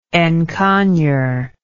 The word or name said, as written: encoignure